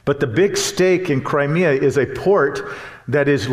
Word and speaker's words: Crimea: But you've been hearing about the news about Russia's invasion of Crimea. That's how it's actually pronounced. That's the English pronunciation of it. It's Crimea.